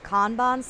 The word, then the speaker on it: Kanban: I believe it's pronounced Kanban system, K A N B A N.